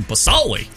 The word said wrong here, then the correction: Pulisic: Pulisic. – So mad he's mispronouncing the name.